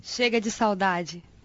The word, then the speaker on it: Chega de saudade: Chega de saudade.